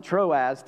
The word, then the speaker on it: Troas: He's going to tell us he's going to a place called, we pronounce it Troas, T R O A Z.